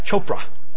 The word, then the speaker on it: Chopra: And there were others, a man by the name of Deepak, I don't know how to pronounce his last name, Shakur? It's sure not related to the spelling of it then. C-H-O-P-R-A. Chopra? Deepak Chopra. Like Oprah with cha. Chopra.